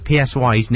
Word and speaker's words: PSY: I was going to try some foreign thing, but you see, I didn't know until a couple of days ago that PSY's name, you actually pronounce it Psy.